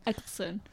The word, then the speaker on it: Eccleston: Eccleston.